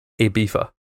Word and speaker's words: Ibiza: So there's a British and European pronunciation, then there's American pronunciation, and the Americans say Ibiza, and Europeans, or it says Spanish and English, say Ibiza.